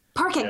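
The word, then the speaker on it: Parcak: I believe her name is Dr. Parekh. – Oh, Sarah Parkak. – Parkak, that's how you say it.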